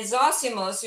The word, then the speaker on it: Zosimus: Am I pronouncing it properly? Yeah, Zosimus, yeah, yeah, of Panopolis.